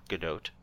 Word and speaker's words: Gadot: I finally heard her pronounce it, so that's how it's said, Gadot.